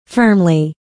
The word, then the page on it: firmly: fə́ːrmli; ファムリィ